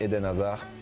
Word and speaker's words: Eden Hazard: Ya sabéis, presentación galáctica esta tarde a las 7 en el Bernabéu de un futbolista cuyo nombre se pronuncia como escuchamos al propio futbolista. – Je suis Eden Hazard, je suis Eden Hazard, Eden Hazard, Eden Hazard.